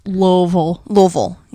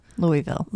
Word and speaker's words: Louisville: Louisville, yeah. Say it like you have a mouthful of shit. – Louisville. Louisville Slugger. – Yeah, that I'll say. – See? – Louisville.